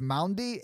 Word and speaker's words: Maundy: And it's only celebrated on Monday, Thursday, which is I'm probably butchering this. It's Maundy. It's M-A-U-N-D-Y, which is Maundy Thursday? – Yeah, it's holy.